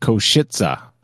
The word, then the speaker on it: Košice: And apparently that's pronounced Košice. Košice. I listened to it about 500 times, and yeah, it does sound like that. Košice.